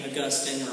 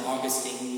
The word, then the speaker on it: Augustine: I mentioned this last week, but in the 5th century, there was a theologian and pastor named Augustine, or Augustine, you can pronounce it either way.